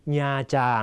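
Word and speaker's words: Nha Trang: เศษนะครับจะวางอยู่หน้ากริยานะครับมีความหมายว่าก็นะครับเช่นแอมกุ๋งหอกเตียงเวียดเออได้หอกเกษตรศาสตร์นะครับน้องก็เรียนภาษาเวียดนามที่มหาวิทยาลัยเกษตรศาสตร์นะฮะหรืออีกตัวอย่างโบกัวแอมกุ๋งทิกดีญาจางนะครับพ่อของน้องก็ชอบไปญาจางคำนี้ต้องอ่านให้ถูกนะฮะอ่านว่าญาจางนะครับเป็นสถานที่ท่องเที่ยวเวียดนามเป็นทะเลที่สวยสุดในประเทศเวียดนามนะครับคนไทยชอบไปนาตรังนะฮะไม่ใช่นะฮะต้องญาจาง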